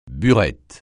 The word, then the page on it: burette: francais